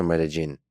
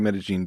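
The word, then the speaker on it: Medellín: Medellin or Medellin? – Both are fine. I think they say Medellin because that's kind of the accent is the zh on the double L, but Medellin would be totally fine as well.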